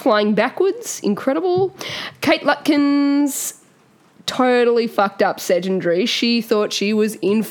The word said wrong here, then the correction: Sedunary: S-E-D-U-N-A-R-Y. – Sedgenary. Yep, Sedgenary. Sedgenary is how you say it.